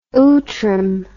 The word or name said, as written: outram